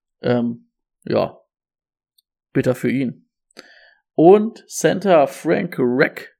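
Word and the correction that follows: Ragnow: Rick, Rick, Rick, Rick, Warte, ich hab's doch vorhin richtig ausgesprochen. Reckno. Reckno. Verlängert um vier Jahre bei den Lions.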